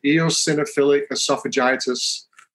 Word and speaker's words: eosinophilic esophagitis: it's uveitis and eosinophilic esophagitis rheumatoid arthritis, and allergic disease.